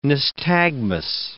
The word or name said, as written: nystagmus